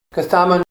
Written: Ksaman

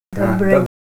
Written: dobra

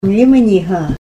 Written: yimaniha

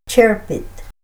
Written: cherpit

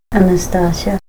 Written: Anastasia

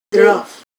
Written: drof

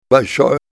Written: balshoy

